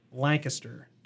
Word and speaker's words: Lancaster: Lancaster, to be clear, is the pronunciation there, Mary.